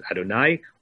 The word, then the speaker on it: Adonai: From here, we get the notion of uh, looking at the letters Yud, Hey, Vav, Hey, and pronouncing as Adonai.